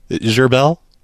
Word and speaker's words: Gherbil: Gerbel. – Spell it, please. – G-H-E-R-B-I-L. That's how you spell Gerbel.